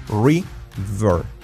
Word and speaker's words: river: Río en inglés es escrito river, pronunciado ri con la R y el efecto U. River, river, river, v, v, v, v, v, river, river. Fijaos, V no es river ni river, no, river, river. ¿Cómo se dice muy en inglés?